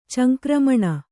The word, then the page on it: ಚಂಕ್ರಮಣ: Kannada